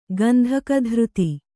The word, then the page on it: ಗಂಧಕಧೃತಿ: kannada